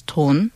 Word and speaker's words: 돈: And some people say seeing pigs in your dream means you'll get rich because a pig is pronounced as ton in Korean Chinese. And it also means money in pure Korean. So it pronounces as the same word as the Korean word for money, 돈, 돼지 돈, and 돈, money.